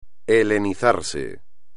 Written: helenizarse

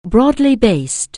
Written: broadly-based

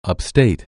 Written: upstate

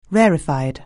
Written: rarefied

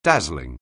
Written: dazzling

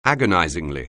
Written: agonizingly

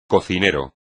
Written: cocinero

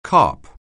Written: carp

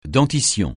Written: dentition

